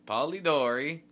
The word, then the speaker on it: Polidori: By the English writer John Polidori. Polidori? – Polidori.